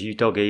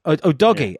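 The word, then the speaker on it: Udogie: I think Ali Gold said it was you doggy. He's had it cleared by... – excellent. – Or whatever country would pronounce it that way. – Whatever country will pronounce it that way. – Sorry, yes. I didn't know where he was from. He is Italian, isn't he? – Yeah. Destiny Udogi. Udogi. Yeah. Destiny Udogi. Do you see the lengths to which The Game Is About Glory will go to get the correct pronunciations?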